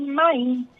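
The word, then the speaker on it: Mahin: Maim mesmo, né? – Que se pronuncia isso. – Eu ainda continuo aqui. – Segundo os historiadores, né? Segundo os pesquisadores dessa personagem histórica tão emblemática, mas é Luísa Maim.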